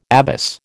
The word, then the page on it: abbess: American English